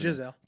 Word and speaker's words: Gisele: They'll probably blame Gisele. Or how you pronounce it? – Giselle.